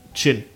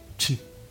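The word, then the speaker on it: Dschinn: Ist D-S-C-H-I-N-N-I. Genie. – Ja, ja, okay. Ja. Mhm. Aber eigentlich nicht Genie, sondern Chin. Mhm. Also auch härter eigentlich. Chin. So. Chin. Ja. Kommt, wie gesagt, aus dem Aramäischen ursprünglich. Ja. Okay. Oder also man spricht es eher so G-Y-N. So spricht man es quasi aus. Also wirklich fast wie den Schnaps.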